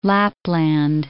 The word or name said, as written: Lapland